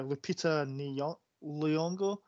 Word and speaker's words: Lupita Nyong'o: Black Panther and 12 Years a Slave star Lupita Nyong'o. – Well, pronounced.